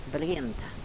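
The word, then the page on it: brenta: dialetto croato di Lukezi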